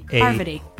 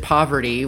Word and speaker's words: Parvati: That right? Parvati. – Yeah, you're saying it correctly. Probst always says it poverty, which is not correct.